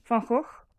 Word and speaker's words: Van Gogh: Van Gogh.